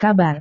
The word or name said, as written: kabar